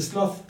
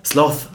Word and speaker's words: sloth: Das ist der Sloth. – Sloth, genau.